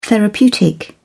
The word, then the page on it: therapeutic: British English